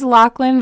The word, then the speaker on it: Laughlin: So our last name is Lachlan, but it's spelled like Laughlin.